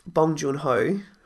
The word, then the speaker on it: Bong Joon-ho: I'd just like to read what Bong Joon-ho...